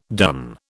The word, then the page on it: done: British English